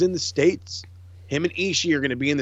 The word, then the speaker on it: Ishii: I think also one of my new favorite things is JR pronouncing both I's at the end of Ishii's name. Ishii. – Mm-hmm. Well, I mean, that's, you're supposed to say Ishii, but I love, yeah, no, it's like when he says it, he adds like 6 E's on the end of it.